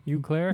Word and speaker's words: Eau Claire: Is that how you pronounce that? E-A-U? Claire? Eau Claire? – Eau Claire. Yeah.